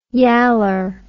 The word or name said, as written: yaller